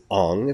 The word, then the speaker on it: Aang: we could no longer call Aang Aang. Because Aang might more properly be pronounced Ong if it was part of a non-fictional universe, and we tried to relate it to names that actually exist.